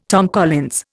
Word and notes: Tom Collins: Indian

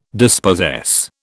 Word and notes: dispossess: British English

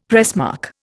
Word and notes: pressmark: Indian

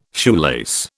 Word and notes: shoelace: British English

